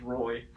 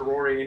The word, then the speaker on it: Rory: I had a coach in eighth grade who pronounced my name the entire year as Roy. And the last day, I was like, it's pronounced Rory.